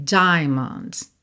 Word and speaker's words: diamonds: Aqui também, olha, assim como lá na palavra geometry, que eu falei para você pensar. Na primeira sílaba, como uma só, né? John, aqui também, diamonds, diamonds, não é diamonds, tá?